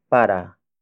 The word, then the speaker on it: para: Up next, we have para. Para. Para. Para is spelled as P-A-R-A.